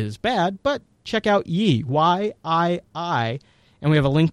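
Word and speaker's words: Yii: Or I think it's pronounced Yi. It's Y-I-I.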